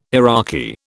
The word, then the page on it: Iraqi: British English